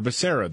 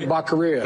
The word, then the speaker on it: Becerra: But instead, he picked Javier Becerra, the former Attorney General of California. – Whoa, whoa, whoa, you're saying it wrong. – Javier Becerra. – Who? – Javier Becerra.